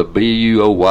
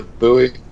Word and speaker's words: buoy: I'm off to swim to the BUOY. I don't know how you pronounce that. Boy? – The buoy. – Buoy? – The buoy. – Oh, yeah, that's it.